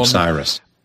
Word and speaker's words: Osiris: Osiris.